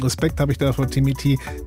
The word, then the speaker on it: Timmy T: Es sind nämlich zwei harte Ds drin. Timmy T.